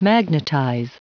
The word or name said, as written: magnetize